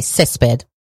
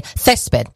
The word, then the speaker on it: Césped: So they do the soft C's. So, you know, like if we were, you know, like Latin American Spanish, they would say Césped, but in Spain Spanish, they say Césped.